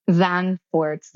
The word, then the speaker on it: Zandvoort: Zandvoort.